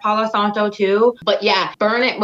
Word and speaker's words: Palo Santo: Yep, the Palo Santo.